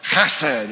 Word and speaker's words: chesed: Stephen's friend, who's a Jewish, he's got a Jewish friend, came over to our house one day and I was talking to him about hesed. He said, no, that's chesed. He told me how to say it.